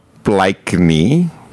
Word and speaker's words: Blakeney: They wanted to hear the Blakey twins, who, when they first went on to Neighbours, Is it Blakey or Blakeney? – Oh, I don't know. – I think it's Blakeney. – It's spelled Blakeney. Blakeney? – Blakeney. – Gail and Gillian Blakeney.